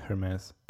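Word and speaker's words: Hermès: Like they partner with Hermes. Is that how you pronounce it? Hermes? – Yeah. That sounds about right.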